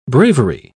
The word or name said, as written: bravery